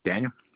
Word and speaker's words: Daniel: Daniel.